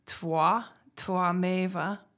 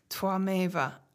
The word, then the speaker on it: tvameva: And it's kind of a, um, it's spelled T V A M E V A, but I believe in Sanskrit it's usually pronounced with a more of a W sound or a combination like Twa, Twa, twa meva.